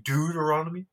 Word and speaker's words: Deuteronomy: I feel like you mispronounced it. – It's dude, right? Deuteronomy? – Yeah, Deuteronomy.